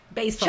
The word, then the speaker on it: basal: Okay, I found this on the web for how do you pronounce basal ganglia. – Oh, basil. – Check it out. – Basil.